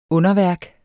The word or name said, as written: underværk